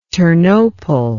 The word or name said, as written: ternopol